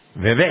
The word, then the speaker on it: Vivek: I'm so pleased with myself now that I know how to pronounce this guy's name. – Vivek. – Like cake. Okay.